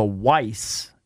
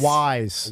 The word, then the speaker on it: Weiss: Is that how you call it? – I think it's Wise. – Wise. – It looks like it should be called Weiss. – Yeah. – But I believe it's pronounced Wise Markets.